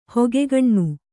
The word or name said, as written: ಹಿಗ್ಗಣ್ಣು